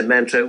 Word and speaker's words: mantra: Okay, well, the mantra is... – Did I say it wrong? – It's mantra's fine. Mantra.